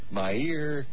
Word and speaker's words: Meir: Yeah, my pronounce, first of all, let's get her name pronounced correctly, Rabbi. And it's Meir. – Meir. – Meir? – Meir Kahana. – Meir. Meir Kahana. – Meir, like, daily.